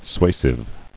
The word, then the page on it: Suasive: sua·sive